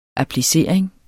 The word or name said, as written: applicering